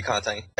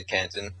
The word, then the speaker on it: Canton: In French, it would be Canton. Every time I say it in English, I just say Canton.